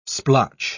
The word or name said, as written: splotch